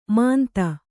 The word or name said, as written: ಮಾಂತ